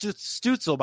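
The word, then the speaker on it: Stützle: So that's pronounced Stutzel, by the way. He's a German. He's a German-born player.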